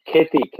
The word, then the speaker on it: KHETEE: Yeah, KHETEE.